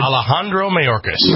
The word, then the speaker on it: Alejandro Mayorkas: Alejandro Mayorkas.